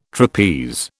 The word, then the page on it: trapeze: British English